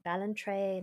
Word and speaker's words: Ballantrae: They set up home at Benane Cave, which is in Ballantrae in Ayrshire, Scotland. Fuck, I don't know if I'm pronouncing any of that right. – You did anyway.